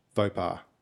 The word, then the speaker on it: faux pas: Four pass. – Oh, it's so close. How'd you know? It's pronounced faux pas.